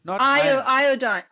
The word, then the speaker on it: iodine: Iodine. Okay, iodine, yeah. – Or iodine. It's pronounced a little bit differently in different countries, so I just wanted to make sure that we had the right word.